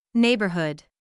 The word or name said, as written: neighborhood